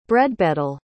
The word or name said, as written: bredbeddle